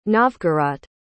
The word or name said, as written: novgorod